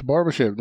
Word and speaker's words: Barbashev: Nej, det är du som säger rätt. Barbashev, det är så man säger.